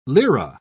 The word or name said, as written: lira